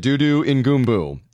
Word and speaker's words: Dudu Ngumbu: and this is how you say it, Dudu Ngumbu.